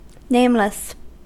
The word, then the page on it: nameless: American English